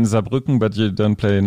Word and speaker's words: Saarbrücken: And then in Germany, we're playing in Cologne, Hamburg, Berlin, Munich, Saarbrücken. Yeah. Am I saying that right? Yeah. yeah. V- yeah.